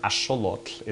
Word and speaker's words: axolotl: They were eating axolotl, or axolotl is the original pronunciation, which are a type of water salamander, the name of which actually comes from the Aztec language.